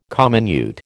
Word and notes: comminute: American English

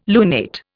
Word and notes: lunate: Indian

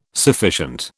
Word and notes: sufficient: British English

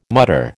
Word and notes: mutter: American English

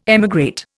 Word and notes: emigrate: Indian

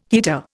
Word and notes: heater: Indian

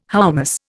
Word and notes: hoummos: Indian

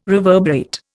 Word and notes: reverberate: Indian

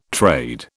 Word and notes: trade: British English

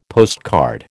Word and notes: postcard: American English

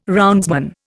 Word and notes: roundsman: Indian

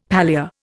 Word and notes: palea: Indian

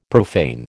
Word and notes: profane: American English

